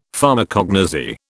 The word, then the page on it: Pharmacognosy: British English